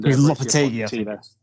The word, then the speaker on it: Lopetegui: And they've narrowed it down to Ten Hag, Julien Lopetegui, I think I'm butchering that name. And Lopetegui. – Okay, there we go.